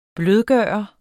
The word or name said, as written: blødgøre